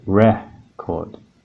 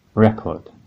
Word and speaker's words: record: So in the two-syllable word, one, two, record, the stress is on the second syllable, chord.